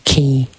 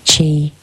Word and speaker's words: Qi: is it spout or pronounced the same way? Qi, Qi, the Chinese in Chinese medicine.